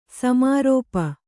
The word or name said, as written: ಸಮಾರೋಪ